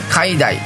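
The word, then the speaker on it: 海内: 海大は、海の内側と書いて海大と読みますが、世の中とか天下を意味していて、無双は二つとないものを意味します。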